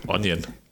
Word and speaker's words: Onion: Wie spricht man die aus? Zwiebel auf Deutsch. – Onion. – Genau, die Onion.